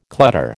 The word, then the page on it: clutter: American English